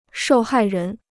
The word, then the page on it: 受害人: shòu hài rén